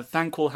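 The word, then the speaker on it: Thanquol: Is that Thankwell? – Yeah, am I saying that right? Yeah, and Ikkit Claw are basically conspiring to get rid of him. And Thankwell has the ear of the council.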